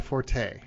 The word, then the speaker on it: forte: Some people insist that it's an error to pronounce the word forte in the expression not my forte, as if the French-delivered word F-O-R-T-E, fort, were the same as the Italian musical term for loud, forte.